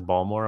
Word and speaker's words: Baltimore: There's no T in his Baltimore.